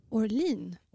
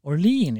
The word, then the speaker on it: Orlean: Det heter Orlean. – Orlean, ja. Som staden New Orleans.